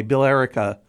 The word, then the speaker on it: Billerica: They put an extra syllable in there and would call it Billerica.